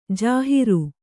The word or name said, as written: ಜಾಹಿರು